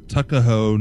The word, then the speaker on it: Tuckahoe: So it says, "Hello, last weekend I was driving on Marshallville Road by the river Tuckahoe, New Jersey. I don't know what time." What are you laughing? It's spelled T-U-C-K-A-H-O-E.